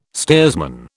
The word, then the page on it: steersman: British English